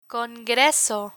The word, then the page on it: congreso: Spanish